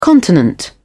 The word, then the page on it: continent: British English